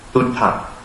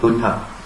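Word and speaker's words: dhuta: 如果巴利文是 Dutta， Dutta，这个头陀台语怎么念，或者是广东话头塔，头陀跟 Dutta 好像还是有点距离。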